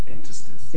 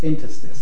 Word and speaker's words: Interstice: Interstice. – Interstice.